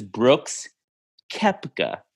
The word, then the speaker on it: Brooks Koepka: Devin, I know you've been drinking some Trulies tonight, but his name is Brooks Koepka. That is how you pronounce it.